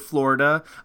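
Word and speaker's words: Flo Rida: I really enjoy the state of Florida. I mean, sorry. I'm pretty sure he pronounces his name Flo-Rida.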